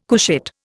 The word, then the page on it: couchette: Indian